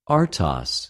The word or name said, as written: ἀρτος